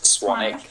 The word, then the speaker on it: Swanwick: You can find me on social media at James Swanick, and that's spelled JamesSwanwick. My name is actually spelled Swanwick, but it's pronounced Swanick.